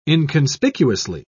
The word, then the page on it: inconspicuously: British English